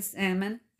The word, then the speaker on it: Salmon: Salmon, Idaho. Now it's spelled salmon like the fish, so I hope I don't pronounce the L.